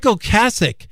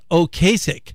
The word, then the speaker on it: Ocasek: And for decades, I've pronounced that name as Rick Ocasek. But when he passed away a couple of years ago, a lot of people had pronounced it wrong. And it finally became clear it was Ocasek.